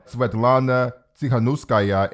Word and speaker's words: Svetlana Tsikhanouskaya: So the leadership, Maria Kolesnikova, Svetlana Tsikhanouskaya, and Veronika Stepkala, those are the three opposition leaders, and I pray that I did not butcher their names.